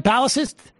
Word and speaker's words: blastocyst: You're not even a blastocyst. Is that, am I saying that right? Blastocyst? – Blastocyst. – A blastocyst, exactly.